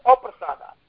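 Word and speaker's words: aprasāda: Because there is a mispronunciation. Yasya aprasada. It is aprasada, the opposite of not getting mercy.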